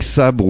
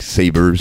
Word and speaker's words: Sabre: Et eh oui, Maverick Sabre ou Maverick Saber, si vous préférez le prononcer à l'anglaise, qui était parrainé à ses débuts par le rappeur-chanteur Plan B, hein, qui était d'ailleurs déjà passé par les transmusicales en, 2005.